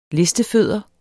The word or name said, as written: listefødder